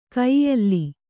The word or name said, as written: ಕೊಯ್ಯಲ್